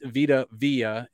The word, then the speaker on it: Vita Vea: It's Vita Vea.